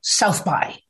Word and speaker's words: SXSW: I just got back yesterday, in fact, from South by Southwest, SXSW, which, by the way, is pronounced South by, SXSW, pronounced South by.